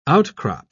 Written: outcrop

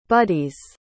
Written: buddies

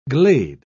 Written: glade

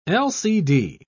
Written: lcd

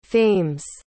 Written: fames

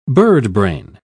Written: birdbrain

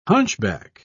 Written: hunchback

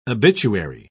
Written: obituary

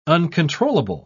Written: uncontrollable

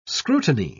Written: scrutiny

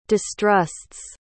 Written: distrusts

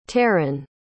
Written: tarynn

